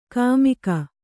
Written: ಕುಮಕ್ಕು

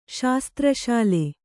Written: ಶಾಸ್ತ್ರಶಾಲೆ

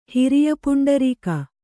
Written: ಹಿರಿಯಪುಂಡರೀಕ

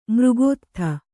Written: ಮೃಗೋತ್ಥ

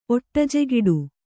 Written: ಒಟ್ಟಜೆಗಿಡು

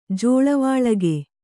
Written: ಜೋಳವಾಳಗೆ

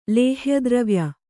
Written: ಲೇಹ್ಯದ್ರವ್ಯ

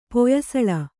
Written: ಪೊಯ್ಸಳ

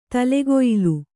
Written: ತಲೆಗೊಯಿಲು

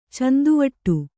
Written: ಚಿಂತೆವಿಡಿ

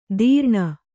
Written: ತೀರ್ಣ

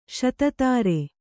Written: ಶತಧಾರ

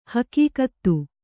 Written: ಹೆಗ್ಗತ್ತು

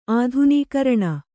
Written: ಆಧುನೀಕರಣ

